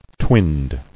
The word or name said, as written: Twinned